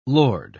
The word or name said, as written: lord